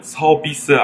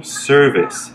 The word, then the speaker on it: service: 맞는 발음은 서비스 아니고 service.